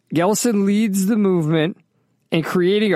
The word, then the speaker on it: GLSEN: So all of this is related to this nonprofit called, I don't know how you pronounce it, Glesen, G-L-S-E-N. How do you pronounce that? – I don't know. That sounds about right.